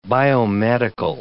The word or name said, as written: biomedical